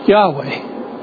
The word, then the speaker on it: Yahweh: And what it does is it takes the vowels from Adonai and puts them in between the consonants of YHWH and you pronounce it Yahweh.